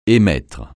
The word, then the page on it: émettre: francais